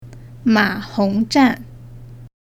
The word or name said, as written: ma hong zhan